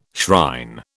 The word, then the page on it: shrine: British English